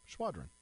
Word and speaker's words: Schwadron: I think it's like a Schwadron. – Okay. – Yeah. – So if you look at how it's spelled there. Throws me off. – Schwadron. – Yeah, Schwadron.